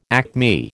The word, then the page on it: acme: American English